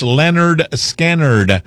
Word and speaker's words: Lynyrd Skynyrd: 53 years ago, 1973, Lynyrd Skynyrd released her debut album, pronounced Lynyrd Skynyrd.